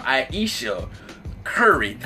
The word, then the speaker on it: Aisha Curry: I got her name right this time. Aisha Curry.